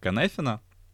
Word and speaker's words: Cynefin: И один из хороших таких фреймворков для этого — это фреймворк Канефина. Он произносится как Канефин, пишется как C-Y-N-E-F-I-N e — это метод,